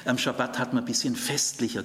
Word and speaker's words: Schabbat: Sag bitte nicht Sabbat, immer betonen auf der zweiten Silbe, Schabbat. Sagt bitte nicht Tora, sondern es heißt Torah.